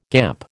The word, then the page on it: gamp: American English